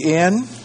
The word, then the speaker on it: Nimbschen: She had been placed in a nunnery in Nimschen. Nimbschen. Nimbschen. A Cistercian nunnery near Leipzig. A Cistercian, I think I spell that, C-I-S-T-E-R-C-I-A-N, near Leipzig.